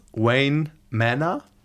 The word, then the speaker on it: Wayne Manor: Oh Gott, wie spricht man das aus? Wayne Manor? – Ja, das gut ist gesagt. Ganz richtig.